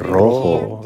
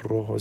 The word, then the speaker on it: rojo: Rojo. – Right? Rojo. So you have to roll your tongue, right? – Yeah.